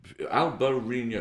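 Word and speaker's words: Albariño: Albarino, it's four syllables, quite a lot for English speakers.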